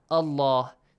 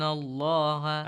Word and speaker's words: Allah: The letter lam in the word Allah and Allahumma must be pronounced heavily with an a sound in case we have a fatha sound or a dhamma sound before the word, right?